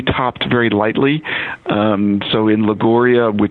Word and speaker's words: Liguria: I also love the history, too, as we travel to, and pardon my Italian pronunciation, I'll need your help here, for classic recipes from Liguria. Is that how you say that? – You've got it perfect.